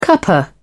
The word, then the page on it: cuppa: British English